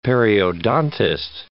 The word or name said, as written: periodontist